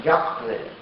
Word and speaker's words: Яхве: И там уже не «эхье», «ашер», «эхье», а стоит всего лишь одно слово, которое филологи сейчас произносят как «яхве».